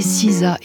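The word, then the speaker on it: SZA: Elle se fait appeler Siza, et ça s'écrit S-Z-A, S comme souveraine et Z comme zigzag.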